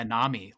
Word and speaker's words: Nami: Is it Nami? Nami ML? – Yeah, Nami, like tsunami.